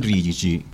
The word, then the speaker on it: Rigi: ¿Por qué el rigi? Porque en castellano nosotros decimos rigi, es verdad, pero la pronunciación correcta es rigi.